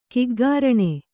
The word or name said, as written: ಗೋಕರ್ಣ